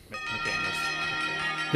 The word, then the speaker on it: McManus: Say it right. McManus. There it is.